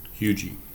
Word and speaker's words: Huger: Just make sure you pronounce it like a local. Say it with us. Huge. So there you go. Pronounce it like a local, they say. Huge.